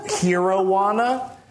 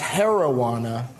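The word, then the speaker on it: Herojuana: But on a recent show, we had an indica that we have here tonight as well that I called Hero-wana. But turns out it's pronounced heroana, because it's like the heroine of weed.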